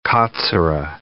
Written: katsura tree

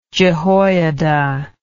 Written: jehoiada

